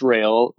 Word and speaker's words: Rail: And there's a picture of me at my sewing machine, and my name is Chris Rail. Last name is spelled R-A-I-L.